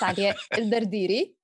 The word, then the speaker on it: Alia El Derderi: Sorry, it's Alia El Derderi. But that's how we say it in Arabic.